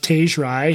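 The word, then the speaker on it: Tage Rai: I mean, I'm happy to name a name here, but I think his name is pronounced Tej Rai.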